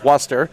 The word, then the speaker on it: Worcester: I was out there, was it last Wednesday, for the matchup against the College of Worcester, which I learned that's how you say it when I got there. I've been calling it Worcester all week.